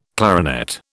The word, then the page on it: clarinet: British English